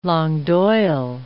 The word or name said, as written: langue d'oil